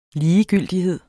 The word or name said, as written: ligegyldighed